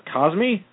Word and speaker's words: Kazmi: but Dr. KAZMI, is that correct? – Yes, Dr. KAZMI. Yep, you got it.